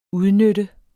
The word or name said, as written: udnytte